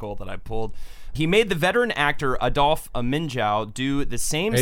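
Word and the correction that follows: Adolphe Menjou: Adolf Manju. – Manju, I knew I was saying that wrong.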